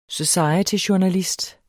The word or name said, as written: societyjournalist